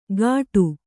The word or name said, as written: ಗಾಟು